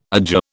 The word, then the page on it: adjure: British English